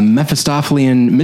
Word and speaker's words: Mephistophelian: It is a beguiling story about a woman haunted by a Mephistophelian, is that how you say it? – That's how I would say it. – Okay. A Mephistophelian mystery man and her newfound telepathic powers,